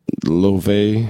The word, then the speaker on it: Laufey: All right, so I think this lady's name is pronounced Lofi. It looks like Laufey,